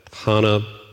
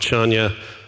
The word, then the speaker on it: Chania: And we were living in this city on Crete called, depending on how you pronounce it, we called it Hana, but some people call it Chania. The people there, I can't pronounce it the way they pronounce it.